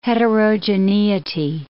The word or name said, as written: heterogeneity